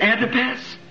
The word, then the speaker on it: Antipas: I guess you pronounce that A-N-T-I-P-S, Antipas? – Yes.